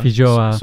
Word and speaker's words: Feijoa: Feijoa.